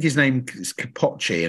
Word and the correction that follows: Kipchoge: and I apologize if I pronounced that wrong. I think I have, or Kipochi, the fastest guy who did it. He broke the world record. Kipchoge. Kipchoge, there we go.